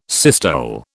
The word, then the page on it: systole: British English